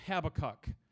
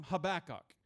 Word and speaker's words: Habakkuk: But his name is, I call him Habakkuk. A lot of people call him Habakkuk. I like Habakkuk better, don't you? That's a better way to say it. It's kind of smooth. Habakkuk.